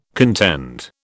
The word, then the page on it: contend: British English